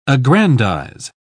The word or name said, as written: aggrandize